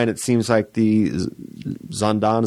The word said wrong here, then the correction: Zidane: is that how you say it? – Zidane brothers, Bill and Glenn.